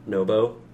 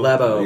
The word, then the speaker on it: Labo: Nobo? – Oh, Labo? – Labo.